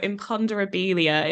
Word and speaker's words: Imponderabilia: Imponderabilia is how I'm going to pronounce this piece.